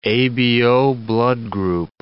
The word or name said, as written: ABO blood group